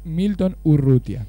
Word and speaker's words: Urrutia: Entonces ahí pasaba la voz de Milton Urrutia, ¿verdad? – Milton Urrutia, sí, por ahí he pronunciado mal, dije Urruti, no, es Milton Urrutia.